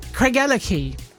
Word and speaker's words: Craigellachie: Craigellachie.